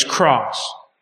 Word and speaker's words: Cross: My name is Cross. – I'm sorry, could you spell it? – Cross, C-R-O-S-S. – Cross, C-R-O-S-S? – That's right.